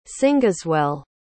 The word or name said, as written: cingeswell